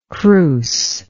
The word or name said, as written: crouse